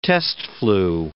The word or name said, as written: test-flew